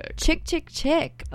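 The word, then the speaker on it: Chk Chk Chk: I think it's Chick Chick Chick. – Okay, we got a pronunciation.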